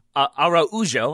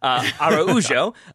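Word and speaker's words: Araújo: Luis Araujo, Araujo, I've learned, is how you pronounce it when you're Brazilian. Araujo, first full season with the team.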